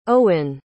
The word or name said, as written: eoghann